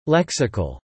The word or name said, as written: lexical